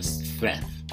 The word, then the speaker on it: strength: So it's strength. It's hard to pronounce for beginners. S-T-R-E-N-G-T-H.